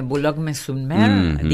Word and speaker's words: Boulogne-sur-Mer: Boulogne-sur-Mer, digo bien, algo así.